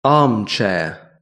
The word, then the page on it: armchair: British English